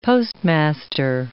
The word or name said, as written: postmaster